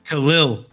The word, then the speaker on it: Khalil: So how did you just say his name one more time? – Khalil Roundtree. – Khalil. There you go. Not Khalil. Khalil. – I tried. Khalil. – Yes. Not Khalil.